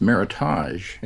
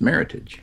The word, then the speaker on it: Meritage: The word looks like it's French, and it looks as if it should be now pronounced Meritage, as the French would. But the term is actually an American term, and it's a combination of merit and heritage, so it's pronounced Meritage.